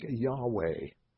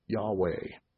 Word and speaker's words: Yahweh: Jehovah, actually they didn't have the J sound. Most scholars today would agree it's probably pronounced something like Yahweh. Yahweh.